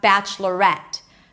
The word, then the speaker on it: Bachelorette: And on the contrary, we have bachelorette. Bachelorette. So we start the same. Bachelorette. Here we do pronounce an A sound at the end, and that's where the stress lies.